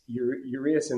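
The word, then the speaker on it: Urías: But the one thing that's working against Urias in this, am I saying his name properly? – You're good.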